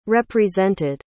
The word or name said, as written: represented